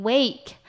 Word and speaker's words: wake: 再我们要来看的是 wake， wake呢指的是醒来的意思，它的中音在WA的地方发A的音， wake 要发长音A，是醒来。